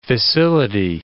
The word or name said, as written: fissility